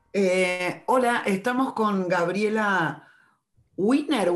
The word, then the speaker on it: Wiener: Eh, en realidad siempre lo hemos pronunciado como Wiener.